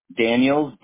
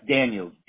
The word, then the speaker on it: Daniels: Yep, Daniels, D-I-N-D-A-N-I-E-L-S, Daniels. – All right. So you said Daniels? – Yes, Daniels, D-A-N-I-E-L-S, like on the bottle, Jack Daniels, I'm Jason Daniels.